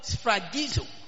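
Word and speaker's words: sphragizo (σφραγίζω): The word sealed is the Greek word sphragizo. The word sealed is the Greek word sphragizo, which is spelled S-P-H-R-A-G-I-Z-O.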